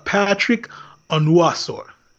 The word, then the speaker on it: Patrick Onwuasor: How do you pronounce the Jets' new inside linebacker's name? – So it's Patrick Onwuasor.